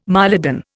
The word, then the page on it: mylodon: Indian